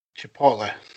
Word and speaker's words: Chipotle: That I've been saying this wrong, right? Chipotle. How do you say that? – Chipotle.